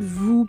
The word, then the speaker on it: vous: S'il vous plaît. S'il vous plaît. Vous, cuidado. Se pronuncia soplando aire entre los dientes superiores y el labio inferior.